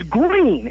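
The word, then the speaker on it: Gruene: It's Green. It's actually pronounced Green. It's G-R-U-E-N-E. And, of course, that's German, Grüne, which means green. So they spell it Grüne, but because we're Texans, we pronounce it Green.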